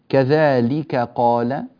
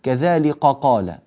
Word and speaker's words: كذلك قال: اقرأوها بهدوء بحيث لا تنقلب الكاف إلى قاف. بعض الناس نسمعه ويقرأ يقول كذلك قال كذلك قال، فيفخم الكاف أو يجعلها تخرج من مخرج القاف، وهذا غير صحيح.